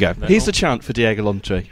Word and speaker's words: Diego Llorente: He put in his weekly piece that Wolves could be in for Diego Llorente from Real Sociedad.